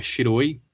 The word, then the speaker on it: shiroi: O porquê do branco é porque tem a ver com a pronúncia, que é shiroi, e o shi, ele também é usado para morte, e também é como se pronuncia o número 4.